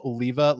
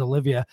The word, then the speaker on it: Oliva: Does it bother you when people mispronounce Oliva like Olivia Newton-John? I have to admit, my first few years of smoking cigars, I mispronounced them. Sorry. – First of all, no apologies. You are one of every single person ever in the history of smoking cigars, says Olivia. For the first about 9.5 years of my career, I corrected people.